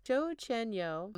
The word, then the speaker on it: Chochenyo: And then the East Bay Ohlone, the Chacho Chienyo, sorry, I'm so bad at pronouncing things. Chochenyo, they convey an image of the mountains as a very powerful place that could mysteriously hide things.